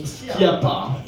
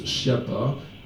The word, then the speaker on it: Schiappa: Marlène Schiappa, en fait, son nom, il est corse, ça se prononce, normalement ça se prononce Schiappa, en corse. Mais il paraît qu'elle, elle a insisté pour que, elle a dit dans une interview, j'aimerais bien qu'on prononce Schiappa à la française. Donc voilà. Et je vous préviens quand même pour les amateurs de linguistique que on dit Schiappa.